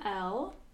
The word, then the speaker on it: Elle: So, my Venmo is my name, L, so that's spelled E-L-L-E, and then the numbers 2794 follow that.